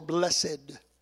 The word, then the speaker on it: blessed: Now, you know, King James, we say it is more blessed. But when we do that, we're actually using King James pronunciations. This word is exactly what it looks like. It is the word blessed.